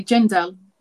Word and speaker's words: Djendel: Okay, Djendel. It's just pronouncing the J, really.